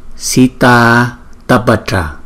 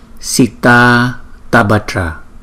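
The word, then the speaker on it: Sitātapatra: Đó, như các bạn thấy là có những điểm chú ý, chẳng hạn như là chữ A, rồi nối tiếp theo là chữ A có dấu gạch ngang, thì chúng ta sẽ gom nó lại thành một chữ A có dấu gạch ngang, thì chúng ta sẽ đọc là Sita Tabatra.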